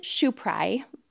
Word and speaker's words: Schopieray: So it's Kristen Schopieray, but it's spelled S-C-H-O-P-I-E. R-A-Y. And you have to say it in that cadence.